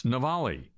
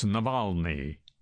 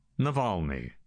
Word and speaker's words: Navalny: And yet he goes on the air on national television in this country and mispronounces, gets the guy's name wrong five or six times. Navalny, he says. It's Navalny. Not Navalny. Navalny. Sure, it's just one letter.